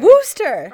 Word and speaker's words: Worcester: Wooster.